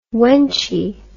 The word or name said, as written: wenchy